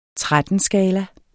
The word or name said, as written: 13-skala